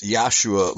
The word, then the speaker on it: Yahshua: And the uh, Nordic languages, the J is pronounced like a Y. So somehow that J sound got picked up from by Latin, uh, transmitted to French, and then finally transmitted to English the correct pronunciation is Yahshua, Messiah, even though there's a J there.